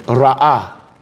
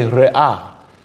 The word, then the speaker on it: re'ah: It is even in the biblical Hebrew, the word friend is not pronounced Hazer, but let me see, it's pronounced friend. Ra'ah. Okay, re'ah is the word for friend in biblical Hebrew.